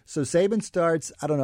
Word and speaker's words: Saban: This was Nick Saban's first year or second year.